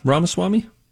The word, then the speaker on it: Ramaswamy: Vivek Ramaswamy? – Yeah, Ramaswamy.